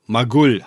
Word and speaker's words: Magull: Gut, zurück zu Magull, habe ich von dir gelernt. – Ja, das ist interessant, dass die Betonung auf der zweiten Silbe liegt.